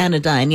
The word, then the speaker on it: Anodyne: And then there's a pool hall named Anodyne. It's A-N-O. – I've heard of that. – D-Y-N-E. Am I pronouncing it correctly? – Anodyne, yeah.